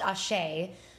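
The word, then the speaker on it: Asé: So, you guys, it's pronounced Ashe, but it's spelled A-S-E with an accent. – Yeah. Which isn't weird. It's just, it doesn't look like it sounds.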